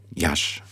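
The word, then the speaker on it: Iași: Das Pogrom von Jassy mitten im Krieg ist zum Beispiel ganz berühmt in diesem Zusammenhang. Jasch wird es ausgesprochen.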